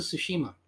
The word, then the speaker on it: Tsushima: Because, like, one thing I absolutely love about my PS5, like, when I was playing Ghost of Tsushima, that game had almost zero load time.